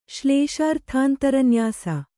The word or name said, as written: ಶ್ಲೇಷಾರ್ಥಾಂತರನ್ಯಾಸ